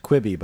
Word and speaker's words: Quibi: It is officially pronounced Quibi, by the way.